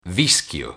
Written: vischio

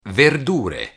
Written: verdure

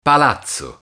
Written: palazzo

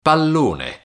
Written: pallone

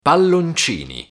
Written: palloncini

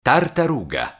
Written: tartaruga

